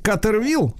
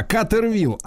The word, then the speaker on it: Катервилл: Иван Александрович, вы, если что, поправьте меня правильное название, как, как произносить, Катервилл, правильно? – О, да, все правильно именно так. – Да, Катервилл.